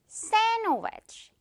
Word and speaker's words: sandwich: 이거 거의 모두가 좋아하는 맛있는 샌드위치인데 우리 한국식 이 표기에서는 샌드위치라고 정확히 표기하지만 자, 규칙상 이 중간에 있는 D는 묵음, 유령 친구가 되어서 날아갑니다. 그래서 어떻게 발음돼요? Sandwich 이렇게 발음이 되는 거예요.